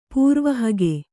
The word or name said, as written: ಪ್ರವಾಹಿಕೆ